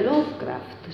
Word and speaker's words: Lovecraft: era Lovecraft, si pronuncia così? Sì, ecco.